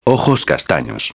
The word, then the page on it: castanos: espanhol